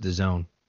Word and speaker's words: DAZN: D-A-Z-N? Yeah. – Yeah, it's called, I think it's pronounced the Zone.